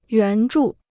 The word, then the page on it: 援助: yuán zhù